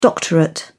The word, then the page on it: doctorate: British English